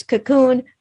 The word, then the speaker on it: Kaqun: And this is spelled K-A-Q-U-N. So we say it's cocoon, but that's the spelling of it.